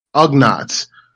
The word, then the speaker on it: Ugnaughts: Dregnox or Ugnaughts.